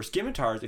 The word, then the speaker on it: scimitars: So for scimitars, if you jump in the scimitars. – Air... – Scimitars. The C is silent.